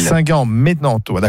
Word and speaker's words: Saint-Germain-en-Laye: C'est Saint-Germain-en-Laye, bref, c'est à côté de Lille. – Saint-Germain-en-Laye, d'accord.